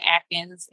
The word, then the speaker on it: Adkins: So you can find me on Facebook and Instagram at Dr. Amanda Atkins. And my last name is spelled A-D-K-I-N-S.